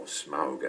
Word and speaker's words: Smaug: I mean, the master of Lake Town, this rather smelly city that's on stilts in the lake below the mountain where Smaug the dragon lives, or Smaug, actually, as I'm supposed to pronounce him.